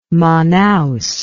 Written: manaos

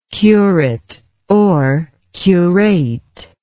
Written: curateship

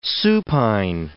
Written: supine

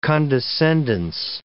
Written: condescendence

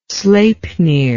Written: sleipnir

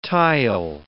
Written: tile

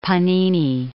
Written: Panini

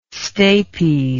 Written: stapes

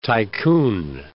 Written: tycoon

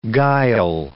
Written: guile

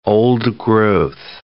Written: old-growth

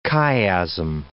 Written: chiasm

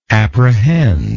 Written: apprehender

